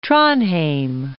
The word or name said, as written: Trondheim